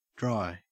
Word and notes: dry: Australian English

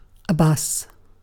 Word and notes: bus: British English

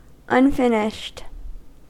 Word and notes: unfinished: American English

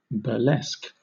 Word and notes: burlesque: British English

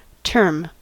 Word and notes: term: American English

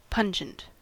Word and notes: pungent: American English

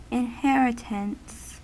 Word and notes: inheritance: American English

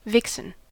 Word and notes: vixen: American English

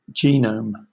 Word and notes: genome: British English

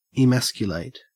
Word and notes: emasculate: Australian English

